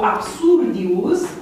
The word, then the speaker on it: absurdius: Deci problema se pune pentru absurdius, are mai multe silabe, mai mult de două, absurdius, patru silabe.